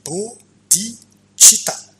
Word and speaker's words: bodhicitta: sa prononciation comme on le dit bodhicitta